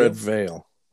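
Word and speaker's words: Redveil: It's a Red Veal. Am I saying that right? – Red. – Or am I saying that right? Red Veal. – Red Veil. – Veil. – Red Veil. – Like a veil.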